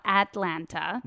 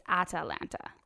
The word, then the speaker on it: Atalanta: And I did double check the pronunciation. It's not Atlanta. – Mm-hmm. – It's Atalanta.